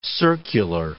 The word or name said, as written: circular